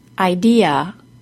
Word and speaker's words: idea: In the word idea, the middle syllable is stressed, and the letter A at the end is said as a. Idea.